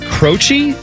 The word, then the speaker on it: Croce: I'm going to go with Croce. It's spelled like Pat Croce, who used to run the Sixers, C-R-O-C-E.